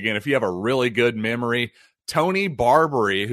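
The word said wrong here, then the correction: Barbee: who you might remember Tony Barbie. I believe it's Barbie, as I struggle to pronounce one of the classic Mattel toy names of all time.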